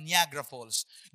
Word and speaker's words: Niagara Falls: This is called, ang pronunciation ko niyan ay Niagara Falls. Di ba? Ganun tayo, Niagara Falls. Pagdating pala, yung totoo palang pronunciation niyan ay Niagara Falls.